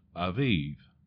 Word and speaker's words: Aviv: You see, in Exodus chapter 13, verse 4, it says, "On this day of the month of Abib," that's A-B-I-B, but it's actually pronounced Aviv, "you are about to go forth."